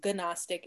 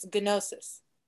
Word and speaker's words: Gnostic: Okay, so we're not pronouncing it right either. So it's Gnostic, it's Gnosis.